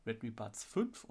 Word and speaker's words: Redmi Buds 5: Die Redmi Buds-Reihe wurde ja, erweitert in die Redmi Buds 5 und Buds 5 Pro. Habe ich das richtig gesagt, Johannes? – Genau.